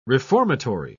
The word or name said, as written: reformatory